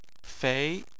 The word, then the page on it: fei: 2